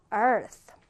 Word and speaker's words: earth: earth. 아, 지금 보면 끝에 이 th 발음을 잘 해주셔야 됩니다.